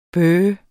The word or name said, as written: bøh